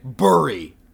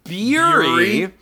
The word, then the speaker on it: Burry: Brian's pronunciation, the word had to be spelled B-U-R-R-Y, as, you know, he rhymes it with hurry in that. – He was wrong with that. – Either he sticks to his guns with his bullshit logic and starts pronouncing it to rhyme with fury, i.e., Beery, or he starts pronouncing it like a normal fucking human American.